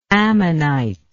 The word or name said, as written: ammonitoid